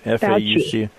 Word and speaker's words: Fauci: Yesterday, during the national presser, Anthony Fauci, is that how you pronounce it, his name? – Fauci.